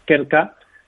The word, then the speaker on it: Krka: De hecho, la pronunciación ya es complicada, ¿no? Es Krka, K-R-K-K-A, es una empresa de Eslovenia,